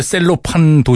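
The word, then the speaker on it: Cellophane: 우리 식으로 읽으면 셀로판 도시입니다. 예. 이 친구들 뭐 셀로페인 이렇게 발음하죠.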